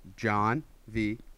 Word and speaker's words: Janvier: I feel like we do a pretty good job with our pronunciation because it's John V.